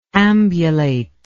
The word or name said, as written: ambulator